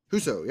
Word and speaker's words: Husso: I said that right? Husso? – Husso, yep.